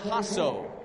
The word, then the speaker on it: Jasso: And here's Dylan Hasso. Hasso, spelled J-A-S-S-O. The J is silent.